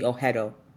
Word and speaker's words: Oghedo: Silent, you can say silent G, Oghedo.